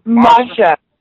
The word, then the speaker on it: Masha: You got to pronounce that first name for me again. What is it? – Masha. – Masha. – Yeah, S-H-A. Masha Slamovich.